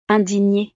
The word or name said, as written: indignee